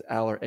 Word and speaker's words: Allar: Drew Aller, I think it's pronounced nice, Aller, A-L-L-A-R.